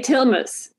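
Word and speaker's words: Tilmes: I'm never quite sure whether it's Simone or Simone, and I'm never quite sure whether it's Tilnes or Tilnes. – I say Tilnes.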